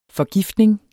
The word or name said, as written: forgiftning